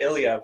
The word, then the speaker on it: Ilya: The first name was right, Ilya, but last name's Brotzky.